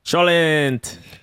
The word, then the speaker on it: cholent: Best churant. – How do we say it better? – Cholent.